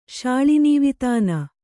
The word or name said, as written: ಶಾಳಿನೀವಿತಾನ